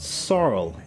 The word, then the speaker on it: Sorrel: If you go to the Caribbean and you have this stuff, they call it Sorrel, S-O-R-R-E-L. For me, that's like trying to say rural or terroir.